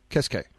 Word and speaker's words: Keske: How did you say his name correctly? – Keske. – Right. Keske. Chasing a guy like Keske, who's got a fair resume and is obviously a very, very popular player across the planet.